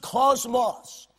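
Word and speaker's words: cosmos (κόσμος): The word "world" here is a Greek word that is pronounced cosmos.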